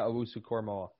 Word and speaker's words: Owusu-Koramoah: It is Owusu-Koramoah, right? Is that perfect. – Right? – Jeremiah Owusu-Koramoah. – Okay, perfect.